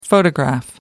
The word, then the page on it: photograph: American English